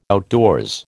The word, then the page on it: outdoors: American English